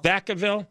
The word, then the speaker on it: Vacaville: Vacaville.